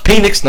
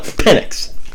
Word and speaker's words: Penix: It's pronounced Penix, not Penix.